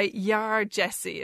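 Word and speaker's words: Yaa Gyasi: And first off is this superb debut novel called Homegoing, by Yar Jessie, is how you pronounce her name. – Now, Yar Jessie is spelled Y-A-A. – Y-A-A, yeah. – G-Y-A-S-I. So where's she from?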